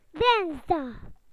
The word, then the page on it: Tuesday: British English